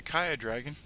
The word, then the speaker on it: Ki'a Dragon: K-I-A-D-R-A-G-O-N. Though I do answer to Kaya Dragon. Either one works. I've mentioned it before. I'm not that picky. But I pronounce it Kia Dragon. No, I don't. Kaya Dragon. You see, even I can't get it right.